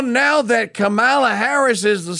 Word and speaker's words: Kamala: By the by, there's a guy has a piece out at the Wall Street Journal about how to pronounce the name Kamala. Kamala. She says it's comma, as in, you know, the little punctuation mark. C-O-M-M-A dash la. Kamala.